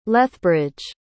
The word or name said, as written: lethbridge